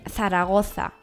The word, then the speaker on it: Zaragoza: Bueno, los españoles dicen Zaragoza, con Z.